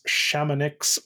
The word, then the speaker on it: Chamonix: He says Chamonix or Chamonix. – Mm-hmm. – That is correct. – Yeah. And I think strategic to give both pronunciations, given that it's an ends in IX. You know, if you're pretty sure that it's pronounced Chamonix, but not 100%, you may want to like pronounce that IX phonetically to make sure you get credit.